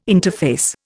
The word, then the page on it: interface: Indian